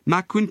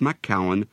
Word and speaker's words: Macoun: Macoun can be pronounced macaon, but I prefer the French-sounding macoun.